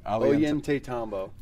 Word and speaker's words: Ollantaytambo: Ollantaytambo.